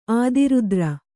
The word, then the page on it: ಅತಿರಾತ್ರ: ati rātra